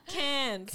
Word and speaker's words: Cairns: Yeah, we flew up to Cairns. Is that how you say it? Cairns? – Yeah. Cairns. – Cairns. – Cairns. More like a can of beer with an S.